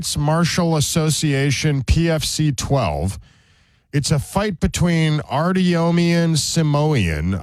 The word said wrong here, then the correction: Artyom Simonyan: I am probably saying his name wrong. They're Armenian, like, kids. – Artyom. – Is that it? Artyom Simonyan.